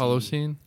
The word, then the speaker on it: Holocene: It's like H-A-L-O-C. – Holocene? – Holocene.